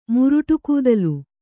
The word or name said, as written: ಮುರುಟುಕೂದಲು